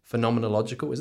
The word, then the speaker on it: phenomenological: But yours specifically, and it's in the title, is phenomenological. Is that how it's pronounced? – Yep.